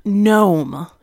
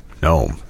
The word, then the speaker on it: gnome: So a deep gnome is how you pronounce it. – Oh, gnome.